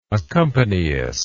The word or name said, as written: accompanyist